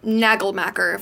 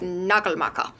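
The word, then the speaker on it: Nagelmackers: Or Nagelmacher, if we want to pronounce it like a dumb American. But I'm pretty sure it's Nagelmacher. So,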